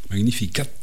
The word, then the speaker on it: Magnificat: Alors ça s'écrit Magnificat, mais il y a un T à la fin et on le prononce Magnificat de Jean-Sébastien Bach.